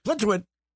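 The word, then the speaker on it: Plitzuweit: If you say Plitzwit real fast, Plitzwit. – You can't mispronounce it.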